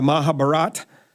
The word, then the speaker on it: Mahabharata: the Mahabharata.